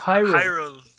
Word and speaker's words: Hyrule: Candance of Hillary. ¿Cómo, cómo lo pronuncia? – No, no, no, no. Cadence of Hyrule.